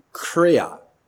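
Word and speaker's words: kreia (χρεία): So I looked up the Greek word for business, and it is the Greek word kreia. I'd spell it with some English letters for you, K-R-E-I-A, kreia.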